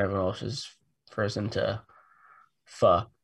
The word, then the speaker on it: pho: And so that may be some confusion, but yeah, it's pho.